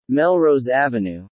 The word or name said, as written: Melrose Ave